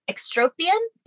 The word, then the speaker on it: ectropion: But one of the things that was on her record was extropion. Is that how you say it? – Yep.